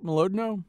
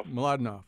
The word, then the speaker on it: Mlodinow: Mlodinow? – Mladenov.